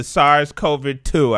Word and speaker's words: SARS-CoV-2: You know, so stay mindful of the SARS-CoV-2.